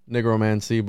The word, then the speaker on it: nigromancy: All right, we made Mark feel uncomfortable there with my pronunciation of nigromancy, but that's how you say it, bro.